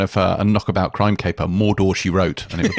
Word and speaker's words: Mordor: And I loved, and I know it's the canonical pronunciation of Mordor, but she goes Mordor, and it just made me laugh.